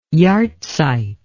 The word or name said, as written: yahrzeit